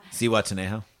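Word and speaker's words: Zihuatanejo: Where's Zanawapatu? – That's not the name of it. – Ziwatanejo.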